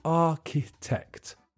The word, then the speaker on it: architect: The pronunciation is tricky because there's a ch in the middle that's just pronounced k, and the middle syllable is weak. So you say architect.